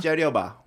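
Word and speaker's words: 째려봐: 재려봐. – 재려봐. She said I was saying it wrong. – Yeah. – She was like trying to fix my pronunciation.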